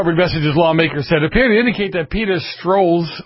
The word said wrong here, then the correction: Strzok: or I can't pronounce that name. Peter Strzok. Strzok. What? Strzok. Yeah, that too.